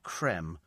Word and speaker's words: creme: It doesn't have a thing, because just like Nestle with the acute accent, so it's still pronounced creme, as in ice cream.